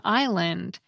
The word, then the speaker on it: island: Crystal writes that this tinkering also resulted in the silent S in island, because the reformers were sure that this word came from the Latin word for island, insula.